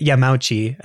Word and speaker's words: Yamauchi: I don't know if he was CEO at the time, but it was Hiroshi Yamamuchi. I can do a quick search. – Okay. – I mispronounced it. It's like Hiroshi Yamauchi, I think.